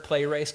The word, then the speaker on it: pleres: The Greek word is P-L-E-R-E-S, pronounced pleres.